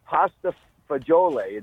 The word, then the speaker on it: pasta fagioli: So it's pasta fagioli.